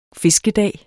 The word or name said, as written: fiskedag